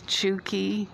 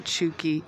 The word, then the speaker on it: Tchouki: I am available to be messaged there at Chuki, initial G, initial L, minor, M-I-N-E-R, and the first name Chuki is spelled T-C-H-O-U-K-I. So that's Chuki, G, L, minor.